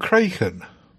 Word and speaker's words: Kraken: I thought it was pronounced Kraken. Do you call it Kraken? – I do call it Kraken because that's how it's pronounced.